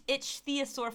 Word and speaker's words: ichthyosaur: Okay, so in Patagonia, an intact pregnant ichthyosaur, it's spelled I-C-H... ichthyosaur, which is like ichthyosaur. – It's an itchy sore.